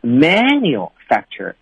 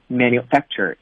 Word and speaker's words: manufacture: 这个词特别好玩哈，就是说它首先是可以作为一个名词出现的，也可以作为动词出现，但是通常呢重音都放在了第二音节，所以我们尽量不要读成 manufacture，你要读 manufacture，是这么读的，这个音呢要往下去压。